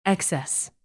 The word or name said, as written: excess